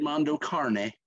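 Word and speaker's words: Mondo Cane: And his album, the Mondo Kane. I believe that's how you pronounce it. – Actually, Mondo Carne. – Mondo Carne. – It means dog's life in Italian.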